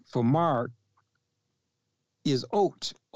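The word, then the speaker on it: oat (אות): But in the English language, it is O-T-H, which is oat.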